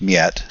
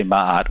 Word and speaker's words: Ma'at: And if you are judged to not be aligned with Miet, we wanted to say Ma'at, which is how it's spelled, but Miet is the kind of this principle of balance and order and harmony.